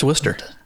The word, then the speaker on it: Worcester: And it's probably not even pronounced Worcester. It's probably pronounced Worcester. – No, it's Worcester.